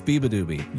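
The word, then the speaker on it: Beabadoobee: Now, see, I said it wrong. It's Beeba Doobie.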